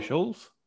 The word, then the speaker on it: Schulz: Andrew Schulz. How do you say his surname? – His surname? – Yeah. – Schulz. – Yeah, because there's no T.